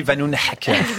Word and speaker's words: Vanhoenacker: Adlan et on dit Vanoun Haker.